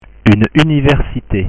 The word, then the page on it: une universite: French